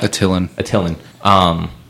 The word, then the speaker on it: Attilan: I can't pronounce their city, Atlian or something like that. – Attilan.